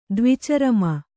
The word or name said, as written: ದ್ವಿಚರಮ